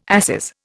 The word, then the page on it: asses: Indian